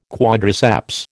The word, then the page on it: quadriceps: American English